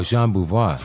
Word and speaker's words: Jean Beauvoir: Is it Gene? Is it Gene or Jean Beauvoir? – Oh, Jean Beauvoir.